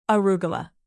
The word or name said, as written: arugula